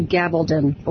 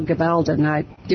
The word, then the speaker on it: Gabaldon: But one favorite series of mine, the author is Diana Gabaldon or Gabaldon. Different people pronounce it different ways. It's D-I-A-N-A, G-A-B-A-L-D-O-N.